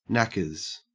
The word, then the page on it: knackers: Australian English